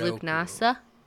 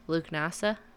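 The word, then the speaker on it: Lughnasadh: The next one is Lugnasa. – Wait, what? – Lugnasa. – Okay. – And it's spelled Lugnasadh.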